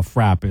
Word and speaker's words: frappe: National, is it FRAP? Is that how you say it? F-R-A-P-P-E, yeah. – Is that called FRAP? Yeah.